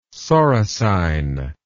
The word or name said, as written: soricine